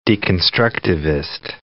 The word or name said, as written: deconstructivist